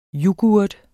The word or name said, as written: Yoghurten